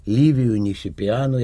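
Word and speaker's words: Liviu Nisipeanu: И вот надо все-таки сказать, по-моему, что вот в этом турнире вдруг сверкнул талант молодого румынского шахматиста Ливию Нисипиану. Я правильно произношу это имя? – Угу.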